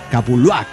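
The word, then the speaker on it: Capulhuac: Kaizo Nave, como diría el buen amigo Mico Miguel, pirata, ya se le escucha un poco mejor cuando dice Capuluac. Y ya nada más, para afinar, póngale un acento en la segunda. Capuluac. Perfecto. Así que estoy.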